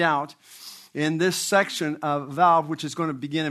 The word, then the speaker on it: Vav: And this section that we look at today is under the title of the sixth letter of the Hebrew alphabet, pronounced Vav in modern Hebrew and in ancient Hebrew, Wow, which is used over 50,000 times as a prefix, mostly as the word and or also.